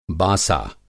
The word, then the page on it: बासा: Hindi